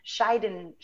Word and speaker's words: Schadenfreude: They have the German word of Schadenfreude.